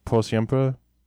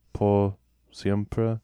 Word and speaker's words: X100PRE: So back on Christmas Eve, Bad Bunny released his debut album, which I believe is pronounced Por Siempre. Por Siempre. It's spelled X100PRE, but as far as I could tell, it's like a abbreviation or sort of manipulation of the phrase Por Siempre, which I believe translates to forever, but I don't know Spanish.